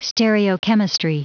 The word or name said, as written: stereochemistry